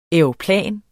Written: aeroplan

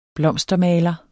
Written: blomstermaler

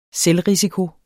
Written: selvrisiko

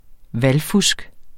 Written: VALGFUSK

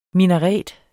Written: minaret

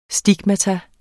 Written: STIGMATA

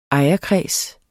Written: ejerkreds

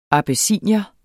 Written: abessinier

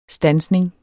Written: STANDSNINGERNES

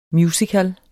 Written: MUSICALEN

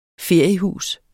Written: feriehus

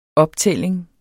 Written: optælling

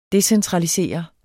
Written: decentralisere

